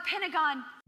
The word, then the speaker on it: Pentagon: I think she said over the pentagogue. Does she know how it's pronounced? – God, the Pentagon. – There you go. She corrected herself. She realized she made a little verbal slip.